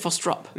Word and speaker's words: Frostrup: More people say Frostrop than Frostrup, but it's Frostrup. – Do they? – Frostrup? You said Frostrup. I think I said Frostrup. Isn't it Frostrup? – You know, I'm not Swedish.